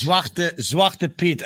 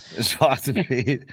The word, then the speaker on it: Zwarte Piet: Zwarte Piet. I feel this is really getting out of hand. – Yeah. – Zwarte Piet.